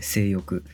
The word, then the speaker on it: 正欲: 正しい欲と書いて性欲って読む